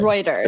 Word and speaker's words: Reuters: So basically, there was a story that was ran by, is it Reuters or Reuters? I can never pronounce this properly. – Reuters. It's Reuters. Reuters.